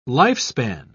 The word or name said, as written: lifespan